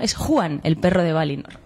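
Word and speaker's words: Huan: Como nos ha dicho Eleder en todas sus clases, muchas veces la H en élfico es aspirada. Pero claro, para nosotros es muy difícil de pronunciar y se confunde con la J. Entonces es Juan, pero claro, es muy fácil que salga Juan el perro, que parece un personaje de un cuento infantil.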